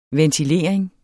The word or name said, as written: ventilering